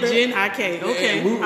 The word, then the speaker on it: Jhené Aiko: Jhené Aiko, okay. He said Jhené Aiko.